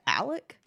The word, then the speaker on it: Alex: Well, everywhere I see it spelled Alex. – Yeah, but I guess he pronounces it Alec.